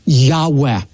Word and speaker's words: Yahweh: It's known as the Tetragrammaton, and most Semitic scholars believe it's pronounced a breathy Yahweh.